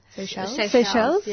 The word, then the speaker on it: Seychelles: from an Indian Ocean island called Seychelles? – Seychelles? – Yeah.